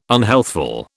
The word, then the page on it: unhealthful: British English